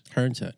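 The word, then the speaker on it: Herns Head: Is that how, am I saying that right? Hearn's Head? H-E-R-N-S Head. – Hearn's Head, yeah.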